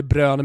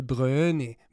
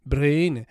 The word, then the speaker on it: De Bruyne: Problemet är att han heter inte Bruyne, liksom Kevin De Bruyne med ö, utan han heter ju no Kevin De Bruyne, Bruyne. Alltså jag har hört han säga det själv.